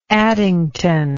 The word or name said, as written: addington